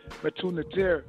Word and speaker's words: Metu Neter: If you're somebody that's fighting the same struggle as me, I would definitely recommend The Medu Netter by Raul Nefer Amen. I would recommend volumes 1-3. I would definitely recommend those. It's actually pronounced Metu Neter. It's spelled Metu Neter.